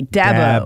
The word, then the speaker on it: Dabo: Say it with me. Dab-o. – Oh, okay. Dabo. – Dabo. Dabo.